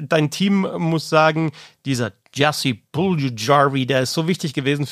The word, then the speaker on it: Jesse Puljujärvi: Jesse Puljujärvi, helfen wir mal mit der Aussprache, Jesse Puljujärvi, der war jetzt für die Florida Panthers in dieser Saison nicht so wichtig, aber ist es eben für die Charlotte Checkers.